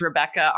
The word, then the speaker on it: Rebekka: Yeah, well, my name is spelled a little differently. So I pretty much, if you search my name on any internet surfer machine, you'll find me. So it's Rebekka, R-E-B-E-K-K-A, Mars, M-A-R-S, like the planet.